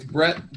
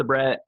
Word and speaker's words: Bret: It's Bret B R E T with one T.